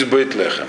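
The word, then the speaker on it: Бейтлехем (בית לחם): Просто Вифлеем в русском произношении – это Бейтлехем.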